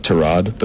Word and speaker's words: Tyrod: It's correctly pronounced Tyrod, but he doesn't correct people.